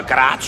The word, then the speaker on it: Greiz: Sie meinen Greiz, also so? – Ja, ja, so schreibt man's, aber Graz spricht man's aus.